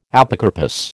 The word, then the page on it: Apocarpous: American English